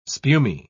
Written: spumy

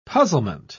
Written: puzzlement

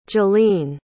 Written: jalene

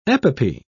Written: epopee

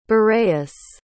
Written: boreas

